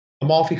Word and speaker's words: Amalfi: Amalfi Coast or Greek islands. Is that correct? – Amalfi Coast, yes. – Which one would you rather? Oh, Amalfi Coast or the Greek islands? – Oh my God.